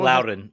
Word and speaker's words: Loudoun: Loudoun United, and am I pronouncing that right? Because I know Loudoun.